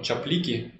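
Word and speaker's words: Czaplicki: Я помню еще сам Эван Чаплики, по-моему, так он сам произносит свою фамилию.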